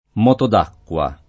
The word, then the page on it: moto d’acqua: lingua italiana